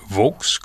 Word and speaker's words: Volks: Il faut se rappeler que la lettre V en allemand se prononce F en français, mais dans l'usage courant au Canada, on prononce « Volks » comme si c'était effectivement un V.